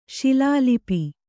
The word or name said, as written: ಶೈಲಲಿಪಿ